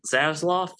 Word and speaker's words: Zaslav: Uh, that was Zaz Zaslav, is how you pronounce it.